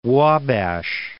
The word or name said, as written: Wabash